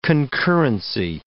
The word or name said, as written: concurrency